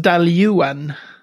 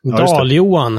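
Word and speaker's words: Dailuaine: Ja, det är den där som uttalas Daljuan. Dal-Johan. Dal-Johan, ja mm. precis. Tyngden på dalskivan.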